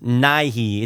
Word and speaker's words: Nighy: Bill, I think it's pronounced Nighy. It's N-I-G-H-Y.